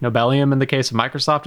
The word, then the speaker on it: Nobelium: I normally give you crap on pronunciations, but I actually think Nobelium is spot on.